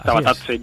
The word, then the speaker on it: Tabatadze: ¿Qué destacarías de un mercado de fichajes que ha traído al equipo amarillo a jugadores como Suso o ¿cómo se dice? Tabatadze, ¿no? ¿Es Tabatadze? – Sí, Tabatadze, Yuri Tabatadze,